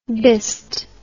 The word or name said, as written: bist